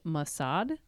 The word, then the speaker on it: Mossad: A Hamas source speaking to the paper claimed the dolphin was an agent of Israeli spy agency Mossad.